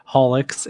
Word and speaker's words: Haulix: So obviously we have tonight yourself, uh, from uh, is it pronounced Horlicks and High Tones? – yeah, it's called Haulix, and the uh, podcast is called High Notes.